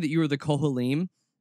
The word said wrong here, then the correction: Kohanim: Kohanim. – Kohanim. Okay. Yeah.